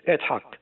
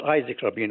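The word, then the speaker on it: Yitzhak: The correct pronunciation is Itzhak. You remember the great the late president of Israel, his name was Itzhak Rabin. It was not Isaac Rabin, it was Itzhak Rabin.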